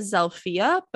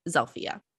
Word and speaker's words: Zilpha: And this girl's name is, they pronounced it Zelphia, but it's spelled Z-I-L-P-H-A. So it kind of looks like Zelpha, but they call it Zelphia.